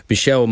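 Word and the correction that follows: Micheál: Martin, definitely that's how I pronounce it, right? – Michal. – Oh, my God. – Not Michelle.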